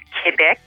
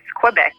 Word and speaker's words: Quebec: Well, it's we're bilingual here, so in French it's Quebec, and in English it's Quebec.